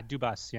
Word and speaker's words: Dubas: I don't know how you say his name. Dubas? – Yeah, Dubas. Yeah. – Dubas, okay. So he was asked about,